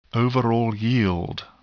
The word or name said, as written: overall yield